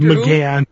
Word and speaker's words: McGann: Paul Megan? Doctor McGann. – Who? McGann. I'm sorry.